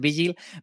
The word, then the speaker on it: Vigil: Vengamos con el número 10, que es mm, Vigil, o Vigil, que como lo pronunciamos nosotros, pero en realidad es Vigil.